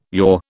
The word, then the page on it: yore: British English